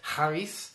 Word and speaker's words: charis: "It's by grace you've been saved by faith," the Greek word is charis. I said that right. I used to live in South Wales, and so that kind of helped. Some of those crazy Welsh words, charis, spelled C-H-A-R-I-S.